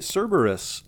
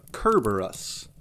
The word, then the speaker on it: Cerberus: So that, of course, would be Cerberus, or perhaps maybe a little closer to the Greek pronunciation, Cerberus. Because, again, the letter C is weird. It's sometimes it makes a hard K sound, sometimes it's an S sound.